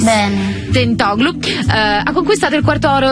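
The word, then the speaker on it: Tentoglou: Stiamo parlando di Tentoglu.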